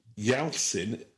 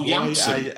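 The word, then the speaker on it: Yalçın: Yalsin Yalman is how I'm pronouncing that. I don't know. – Mousy Meowman. Yeah, okay, well, that's how you're choosing to interpret. – That's what you said. – Yalsin. – Oh, Yalsin. – Y-A-L-C-I-N.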